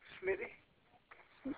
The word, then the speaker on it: Smitty: Smitty. – Okay, spell that for me. – S-M-I-T-T-Y.